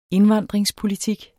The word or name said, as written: indvandringspolitik